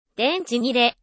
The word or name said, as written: 電池切れ